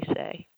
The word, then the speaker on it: Hsieh: And if you want to search and find out more about Tony, his last name is spelled Hsieh, and it's pronounced Tony Say.